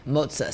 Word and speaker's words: Mozza: How do you say it? – Mozza. So think mozzarella.